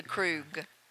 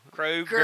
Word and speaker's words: Krug: Now the G sound's still there. – Yeah, he gets the Krug. We're getting rid of er. Well, yeah. Maybe it's that southern thing. I want to add another syllable.